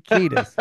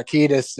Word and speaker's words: Kiedis: Like, I got out of being embarrassed and was cool, and then, like, didn't know it was Anthony Kiedis. That's how you pronounce it, right? – Yeah, I think so. Yeah, Kiedis.